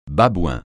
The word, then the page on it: babouin: francais